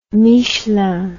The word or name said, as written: Michelin